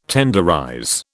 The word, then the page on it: tenderize: British English